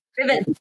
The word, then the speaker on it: Ruthven: Ruthven, Count Ruthven. – Riven. It's Lord Riven. It's pronounced Lord Riven.